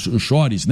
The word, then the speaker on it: chores: Chores, né? – É, chores. – Aí a pronúncia é chores.